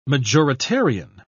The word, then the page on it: majoritarian: British English